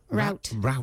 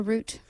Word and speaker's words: route: Route. Like a root. – Oh, a root. – Yeah, you can pronounce it either way.